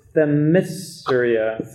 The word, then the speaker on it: Themyscira: This is Themyscira. So, help. – Nailed it. – Themyscira. – No, he nailed it the first time. – Yeah? – It's fine. – Themyscira.